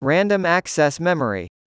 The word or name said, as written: Random Access Memory